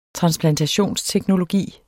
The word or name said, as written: transplantationsteknologi